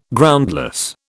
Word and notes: Groundless: British English

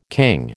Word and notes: king: American English

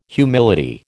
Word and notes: humility: American English

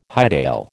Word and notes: hightail: American English

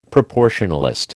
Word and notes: proportionalist: American English

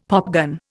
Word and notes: popgun: Indian

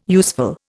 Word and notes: useful: Indian

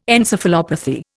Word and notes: encephalopathy: Indian

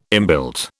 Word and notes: inbuilt: British English

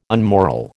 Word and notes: unmoral: American English

